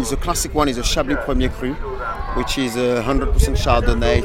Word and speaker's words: Chablis Premier Cru: Chablis Premier Cru.